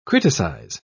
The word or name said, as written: criticize